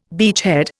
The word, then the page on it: beachhead: Indian